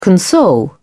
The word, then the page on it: console: British English